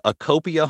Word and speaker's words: ACOPIA: It is ACOPIA Home Loans.